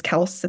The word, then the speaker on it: Kelce: How apparently Travis Kelce, his name is Kels.